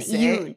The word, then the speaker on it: Iwan: Dafydd Iwan. Is it Iwan? Oh, yeah, because it's I-W-A-N. Iwan.